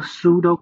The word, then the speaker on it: pseudo: 这个畏途击队模式啊，英文叫做 pseudo commando，拼法是 P S E U D O C O M M A N D O，最前面那个 P 是不发音的。